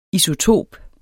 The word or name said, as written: ISOTOP